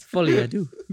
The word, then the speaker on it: Folie à Deux: Well, yeah, it's Folie à Deux.